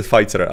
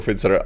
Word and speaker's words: Pfizer: ale že čte Fighter. ano. Jde, jde o to, to je mimochodem, to je, protože já jsem to vyslovoval celou dobu jako Fitzer a mám tu tendenci to dělat stále,